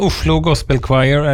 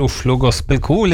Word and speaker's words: Oslo Gospel Choir: Men Oslo Gospel Choir, eller Oslo Gospel Kor, litt etter hvordan du uttaler det, skal vi ha litt fokus på de neste minuttene.